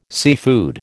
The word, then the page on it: seafood: American English